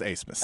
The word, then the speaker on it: Abmas: It is spelled Abmus, but it is pronounced Acemus.